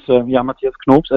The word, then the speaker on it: Mathias Knopps: My name is Mathias Knopps, as you would pronounce it in German, which is totally fine with the English pronunciation.